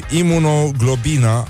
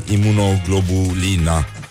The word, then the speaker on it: imunoglobulina: Și imunoglobina Așa cum pronunță premierul României Imunoglobulina Ceea ce ne arată că doamna premier probabil că pune în bradul de Crăciun globul Line.